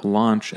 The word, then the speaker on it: launch: And the word launch is pronounced like the word lawn or the word on. Or as you know, we have another host here, Sean, right? It's that sort of on sound. Whereas the next word, lunch, right, is pronounced more like punch, right? Lunch or under is that sort of sound. Lunch, punch, launch, lawn. So just watch the difference between those.